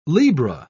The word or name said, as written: libra